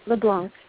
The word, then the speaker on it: LeBlanc: Yep, LeBlanc.